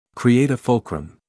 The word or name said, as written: create a fulcrum